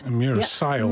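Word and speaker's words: Meera Syal: Comedian Mira Sial, zeg ik dat goed? – Mira Sial.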